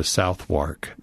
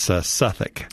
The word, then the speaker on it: Southwark: Yeah, and don't go to London and tell them that you want to go to Southwark. – Yeah. – You'll never get there. – Okay, so when I go in a week and a half, I shouldn't say that. – No, it's Suffolk.